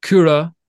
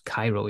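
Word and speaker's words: Kyro: So, you know, Kura, which we know how to pronounce now because he was on the show. But if you guys are wondering what we're talking about with Kura, it's K-Y-R-O. So it looks like, well, I don't know what it looks, Cairo. Yeah, I suppose it looks like Cairo instead of Kura. But obviously being Finnish, that's the way they pronounce it.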